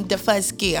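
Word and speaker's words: Daufuskie: From the island, that's how I pronounce it. I say I'm Defusky. I'm from Defusky, but we're saying the first key island that we landed.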